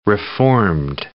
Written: reformed

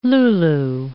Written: lulu

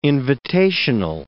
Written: invitational

